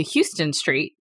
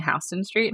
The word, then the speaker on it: Houston Street: I was going to a restaurant around Houston Street, and I was asking my coworkers, are we going to Houston Street? Because that's how it's spelled. They were like, you mean Houston Street?